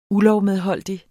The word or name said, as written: ULOVMEDHOLDIGT